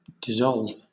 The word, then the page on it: dissolve: British English